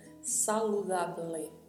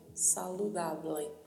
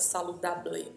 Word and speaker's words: saludable: Saludable, saludable. Lembre-se sempre que a letra B tem um leve som de V. Então não iremos pronunciar de maneira muito marcada saludable, não. É saludable, saludable.